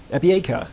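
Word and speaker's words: epieikeia (ἐπιείκεια): The Greek noun is epieikeia. Epieikeia, that's your good. Emphasis on the third syllable, epieikeia, whatever.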